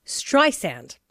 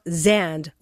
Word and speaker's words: Streisand: It's Streisand, or as she says, like sand on the beach, not Zand with a Z.